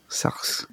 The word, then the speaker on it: Sartre: You probably pronounce like the R-E a little bit, but like Sartre.